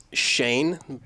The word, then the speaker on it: Schoen: It's Schoen, but it's supposed to be Schoen with an umlaut right. – In German.